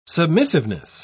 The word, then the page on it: submissiveness: British English